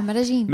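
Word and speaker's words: Medellín: We started in Bogota, the capital of Colombia, and then we moved to Medellin. Did I pronounce that right? – Yeah, Medellin.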